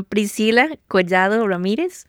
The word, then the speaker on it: Priscilla Collado Ramirez: I'm Priscilla Collado Ramirez.